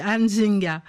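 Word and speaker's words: Nzinga: voilà le vrai prononcé de ce nom, c'est Anzinga.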